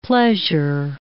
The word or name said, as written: pleasure